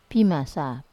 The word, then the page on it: pimasa: wamesa